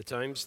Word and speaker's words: Thames: The Thames, thank you.